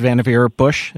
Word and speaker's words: Vannevar Bush: and I believe it's pronounced Vannevar Bush. Is that how you pronounce it? – That's right. Yeah.